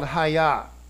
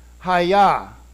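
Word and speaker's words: hayah: Professionally or it's called Haya. That's the phonetic pronunciation of it. Haya, which is to be.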